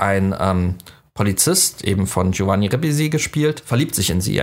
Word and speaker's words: Ribisi: Ribisi, so wird er ausgesprochen.